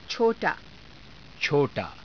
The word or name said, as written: cho_taa